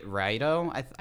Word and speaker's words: Raito: Raito. I hope I'm pronouncing that right. It's like R-A-I-T-O.